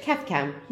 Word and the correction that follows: kaftan: No. Kaft. – Kaft. – Tan. – Tan. – Kaftan. – Kaftan. – Yes! That's the first time I've ever heard you say it right.